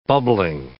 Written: bubbling

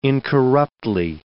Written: incorruptly

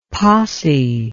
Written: possie

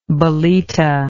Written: bolita

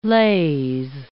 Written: lase

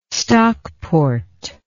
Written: stockport

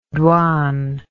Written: douane

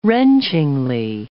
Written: wrenchingly

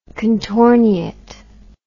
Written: contorniate

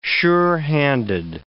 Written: sure-handed

